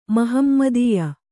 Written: ಮಹಮ್ಮದೀಯ

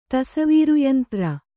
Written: ತಸವೀರುಯಂತ್ರ

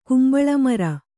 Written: ಕಂಬಳಿಮರ

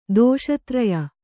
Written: ದೋಷತ್ರಯ

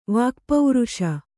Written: ವಾಕ್ಪೌರುಷ

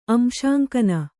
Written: ಅಂಶಾಂಕನ

